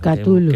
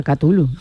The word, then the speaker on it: Cthulhu: ¿Esto cómo se dice? – Cthulhu. Que mi hijo me lo dijo, Cthulhu, que es un fanático de este tipo de obra. – Cthulhu. Es verdad, Cthulhu. ¿Qué es lo que los convierte en un pilar del horror cósmico y del miedo a lo desconocido? – Bueno, voy a decir una barbaridad.